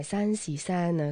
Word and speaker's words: 三系三: 嗱，呢位王礼格咧喺浙江温州出世啊，咁佢嘅专辑咧第一张就叫做《三系三》，系温州话嚟嘅，发音咧就系山是山啊，咁意思即系话好靓仔，好靓仔咁解。